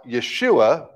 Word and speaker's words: Yeshua: And in Jesus' time, it would have been pronounced Yeshua, but written as, and that's actually an I there at the beginning.